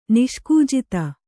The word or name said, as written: ನಿಷ್ಕೂಜಿತ